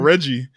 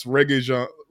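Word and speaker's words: Regé-Jean: And then I wasn't even saying his name right. I was calling this man Reggie. And she was like, Reggie isn't his name. And so I looked it up. It's Reggae John.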